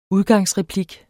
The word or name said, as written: udgangsreplik